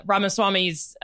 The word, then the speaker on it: Ramaswamy: Though, your point about Ramaswamy's main Rise.